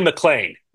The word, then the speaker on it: McLean: So it's first one, Nolan McLean. Okay. – Yeah, I think McMaine. Yeah. Rhymes with McMaine.